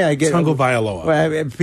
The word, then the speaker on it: Tagovailoa: Tagovailoa. – People say that's not right. – Even though there's no N in it. – There's no N in his name.